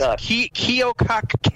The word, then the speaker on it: Keokuk: By the way, Bill, the town in Iowa that she was pronouncing is Keokuk, K-E-O-K-U-K.